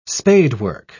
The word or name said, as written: spadework